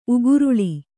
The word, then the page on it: ಉಗುರುಳಿ: uguruḷi